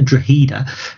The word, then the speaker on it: Drogheda: If they're Drogheda, they pronounce the G in their nickname, but not in their actual name.